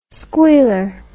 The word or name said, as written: squealer